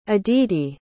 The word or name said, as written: aditi